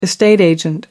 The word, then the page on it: estate agent: American English